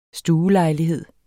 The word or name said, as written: STUELEJLIGHED